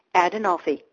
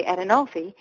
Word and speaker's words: Adenolfi: When people first look at my name, it seems hard to say, but it is not if you break it down into four syllables or four parts. Ad-in-al-fi, Adenolfi. If you can say macaroni or mozzarella or zucchini, you can say Adenolfi because it is an Italian name.